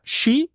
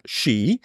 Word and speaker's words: Chi: Xi, perché si scrive chi, ma si pronuncia Xi,